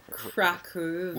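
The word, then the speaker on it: Kraków: And how indeed do you pronounce it? – Krakow, if you want to pronounce it, you know. – Krakow. – With a Polish accent. – Okay. – Accent, you would say Krakow. – Because it's spelled K-R-A-K-O-W, so it could be Krakow. – You know, I've gotten used to it. Most people say Krakow, most foreigners and travelers, I guess.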